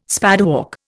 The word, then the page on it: spadework: Indian